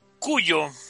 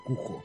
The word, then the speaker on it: Cujo: Cuyo. Sí se pronuncia así, va, carnal, Cuyo. – Sí, el nombre es Cuyo, pero pues en toda este, América Latina dicen Cujo.